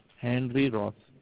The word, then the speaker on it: Henry Ross: My name is Henry Ross. – Andy Ross. Okay, so can you spell that for me? Because I'm going to write it down. Andy Ross. A-N-D-Y? – H-E-N-R-Y. Henry Ross.